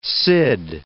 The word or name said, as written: Cid, El